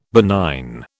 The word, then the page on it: benign: British English